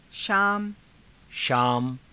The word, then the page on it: shaama: Hindi